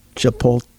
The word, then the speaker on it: Chipotle: Chipotle.